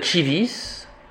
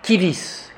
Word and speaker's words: civis: Au lieu de Chivis, C-I-V-I-S, on va prononcer dans la prononciation restituée kivis, kiwis. Donc ici, on prendra la prononciation romaine.